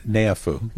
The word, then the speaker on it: Neiafu: Uh, Neiafu.